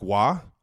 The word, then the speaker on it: Roy: I got Patrick Waugh, also known as Patrick Roy in the Kelsey household, because dad only pronounces things one way, his way, a la our last name.